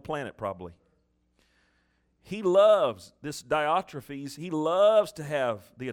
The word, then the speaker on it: Diotrephes: I wrote unto the church, but Diotrephus, how do you say that? How is it, Homer? Diotrephes. Diotrephes, who loveth to be or to have the preeminence.